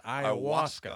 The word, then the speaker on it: ayahuasca: I believe the correct pronunciation is ayahuasca. – Ah, whatever. – Or ayahuasca.